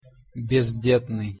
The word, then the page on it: bezdetnyj: Russian